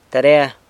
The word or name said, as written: tarea